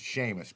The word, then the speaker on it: Seamus: Anyway, it's Seamus, people, just like the word Sean. You know, sometimes Sean is S-H, but we've been able to get on board with Sean Connery and the rest that spell it S-E-A-N. Seamus, all right? Not Seamus.